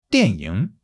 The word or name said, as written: dian4 ying3